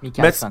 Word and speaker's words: Mikkelsen: Bunu Matt Mik Michaels, Mikkelsen diye okunuyor galiba. – Mikkelsen.